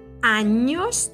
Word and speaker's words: años: Und la pronunciación de la ñ es como la CG en coñac, coñac. Igual. Por ejemplo, ¿cuántos años tienes?